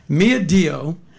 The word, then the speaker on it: Mia Dio: Mia Dio, is that how you say it? – Yes.